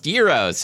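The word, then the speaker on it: gyro: And you've most likely seen their posters at your local Euro place, like, you know, pronounced Euros.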